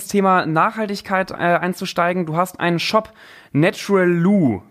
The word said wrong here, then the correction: Naturalu: wenn ich das richtig ausspreche. – Nee, Naturalu. – Naturalu, Naturalu, okay.